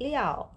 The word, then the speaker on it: 料: liao.